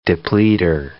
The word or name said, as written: depleter